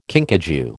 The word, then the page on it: kinkajou: American English